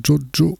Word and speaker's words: Tjotjo: On va continuer toujours dans les nouveautés, cette fois-ci avec Jojo Records. Ça, je le dis comme ça parce que ça s'écrit T-J-O-T-J-O, Jojo Records, Big Word for My Little Bird, et donc c'est de Netherlands.